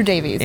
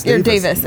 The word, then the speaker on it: Davies: And Andrew Davies. – Davis. – Yeah, he pronounced it Davis. It was spelled Davies, case you want to look him up.